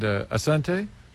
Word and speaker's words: Asante: And Asante, is that correct? – Yeah, Karibu.